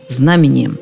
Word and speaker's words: знамением: Обратите внимание, здесь именно такое ударение – знамением – Крестное знамение, крестный ход, но крестный отец, крестная мать.